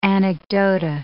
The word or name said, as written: anecdota